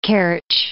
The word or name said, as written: Kerch